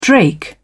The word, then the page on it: drake: British English